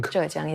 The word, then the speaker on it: Zhejiang: And we discussed that with company Minth from the province Zhejiang. – Zhejiang, yes, right.